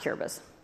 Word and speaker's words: Kiribati: Kiribati, it's spelled Kiribati, but it's pronounced Kiribati. Long story.